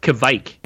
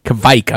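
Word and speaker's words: Kveik: You can check that out, along with the Kvach yeast. I don't know if that's actually how you call it, but I'll look it up later. – It's pronounced Kvike. – Kvike. I was close.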